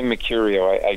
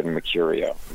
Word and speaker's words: Mecurio: But my family never pronounce the first R. We've always said Mercurio. We've never said Mercurio. – Yeah. – Because we're from Rhode Island, and we only pronounce, like, every fifth R.